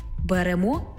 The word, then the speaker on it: беремо: У дієсловах на емо, імо, ете, іте наголошуємо останній склад. Беремо, берете, веземо, везете, несемо, несете, ідемо, ідете, п'ємо, п'єте.